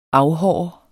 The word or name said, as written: afhåre